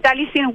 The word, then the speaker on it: Taliesin: Me interesaría que nos contaran un poco, para que todos aprendamos, sobre el texto que se llama, no sé si se pronuncia Taliesin o Taliesin. – Ah, sí, Taliesin West.